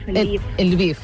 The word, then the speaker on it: Lviv: Ella viu a Lviv, no sé si pronuncio bé el nom d'aquesta ciutat. Natàlia, bon dia, buenos días. – Hola, buenos días. Sí, se le llama Lviv. – Lviv. – Correcto.